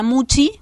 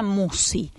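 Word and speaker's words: Musci: No se pronuncia Muchi, como estamos acostumbrados a escuchar, sino que se pronuncia Musi. Y se escribe SC.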